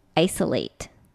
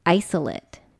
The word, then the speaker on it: isolate: So when this word becomes a noun, the pronunciation changes slightly. When it's a verb, it's isolate, isolate. But when it's a noun, it's isolate.